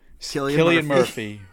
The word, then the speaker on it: Cillian Murphy: And now Cillian Murphy, whose name I'm going to pronounce correctly, finally.